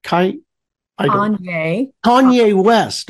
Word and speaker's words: Kanye West: Kanye West.